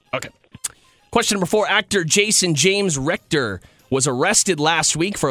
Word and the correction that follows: Richter: At age 11, Rector, am I saying that right? – Richter.